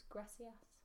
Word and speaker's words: gracias: In Latin America, they still pronounce gracias. – It as a C, so it's gracias.